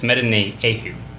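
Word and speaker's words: Metaneuho (μετανοέω): It's metaneuho. Metaneuho. That's how you say it.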